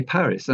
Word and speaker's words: Paris: And of course, you might say, well, that's not the French pronunciation. Very similar to the French pronunciation 1,000 years ago. I imagine that the French pronunciation round about the year 1,000 would have been Paris, with the final S pronounced.